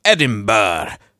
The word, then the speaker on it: Edinburgh: University of Edinburgh, UK, is reporting. – I know it's Edinburgh, but it says Edinburgh. Why do you have to sling another syllable in there?